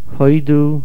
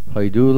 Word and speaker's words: hoidu: Hoidu, in this mizmor, the stress is on the hey. However, in the tefillah of Hoidu l'Hashem kir'u v'shmoy, Hoidiyu v'amim ali l'isav, the stress is on Hoidu. Hoidu. The answer is that, according to the rules of Diktuk, the word should be hoidu.